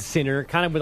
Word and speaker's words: Sinner: So I went to the ATP website, and they have Yannick saying his last name, and it has a little bit of a different emphasis on the end. So, yeah, it's Yannick Sinner.